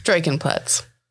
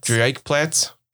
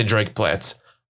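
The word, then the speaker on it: Dreieck Platz: D-R-E-I-E-C-K Platz. – Draken Platz. – There's no N. – Drake Platz. – I'm just gonna say Drake Platz.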